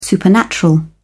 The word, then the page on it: supernatural: British English